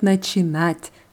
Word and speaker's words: начинать: Und das Verb начинать wird genauso konjugiert wie завтракать, nur die Betonung ist hier immer auf dem hinteren A.